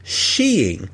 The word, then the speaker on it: skiing: The narrator and the opening titles mention an alternate pronunciation of skiing as sheeing, although the narrator pronounces it as skiing for the remainder of the short.